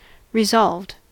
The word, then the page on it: resolved: American English